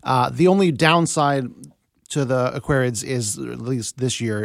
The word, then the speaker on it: Aquarids: Yeah, Aquarids is usually how it's pronounced.